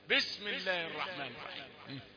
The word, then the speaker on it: بسم الله الرحمن الرحيم: كتير من القراء ما نسمعش منهم الباء دي. بسم الله الرحمن الرحيم. يبقى الباء ضعيفة لسه بيسخن. نقول له لا ما عندناش كلمة تسخين. من أول ما تنطق هات الحروف كلها سليمة.